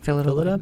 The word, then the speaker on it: Phyllida: And then they bring on director Phyllida Lloyd. – Yep. – That's how you say her name, right? – Yep, Phyllida.